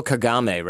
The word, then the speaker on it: Kagame: Is the current president of Rwanda, that's Paul Kagame, right? Correct.